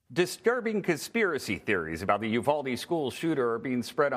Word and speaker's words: Uvalde: I believe it's Uvalde. Is that right? – If you're, yeah, in Texas, I've always heard it Uvalde. But if you're a Spanish speaker, it's Uvalde. But yeah, it's Uvalde.